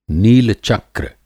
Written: नीलचक्र

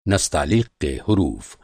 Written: नस

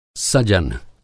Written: सजन

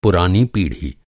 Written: पीढ़ी

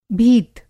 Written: भीत

